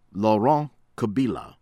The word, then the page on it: Laurent Kabila: American English